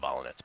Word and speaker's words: Volynets: Her last name is Volynets. She became a professional tennis player. She pronounced it Volynets, by the way.